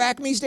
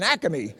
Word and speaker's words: Acme: Do you have Acmes down here? In Philly, it's A-C-A-M-E-Y, right? Acme. It's an Acme. It's 4 letters, 3 syllables.